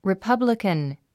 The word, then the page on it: republican: ripʌ́blikən; リィパブリカン